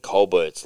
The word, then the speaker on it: Colbert: whatever his name is, Stephen Colbert's like fucking non-stop political. – I believe the T is silent. It's Colbert.